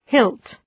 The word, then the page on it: hilt: hılt